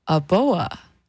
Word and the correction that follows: Aoba: Now, if I said it incorrectly, oh, I think I said it right, Aoba. There we go.